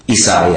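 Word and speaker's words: Eisai: I don't know if I'm pronouncing it correctly, but Esai? – Esai, yes.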